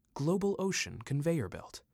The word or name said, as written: Global Ocean Conveyor Belt